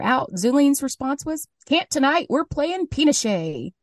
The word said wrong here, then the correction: pinochle: Or not Pinochet, Pinochle. – Pinochle.